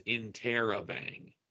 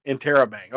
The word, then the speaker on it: interrobang: And the pronunciation on Google is interrobang. – Interrobang. Okay. Yeah. Not interrobang. It's interrobang.